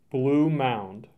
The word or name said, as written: Blue Mound